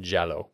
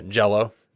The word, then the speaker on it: Giallo: And then there's the whole Gallo like movement. – Giallo. Yeah. – Yeah. I'm not Italian, so I don't know how you pronounce it. – Yeah. If you want to say, yeah, Giallo. Jilo.